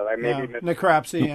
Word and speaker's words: necropsy: I think they have one of the top labs for, if I pronounce this correctly, necropsy. I'm not positive. – Necropsy, yeah.